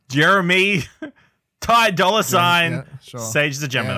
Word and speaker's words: Ty Dolla Sign: It's Ty Dolla Sign, I believe is how you say the person's name.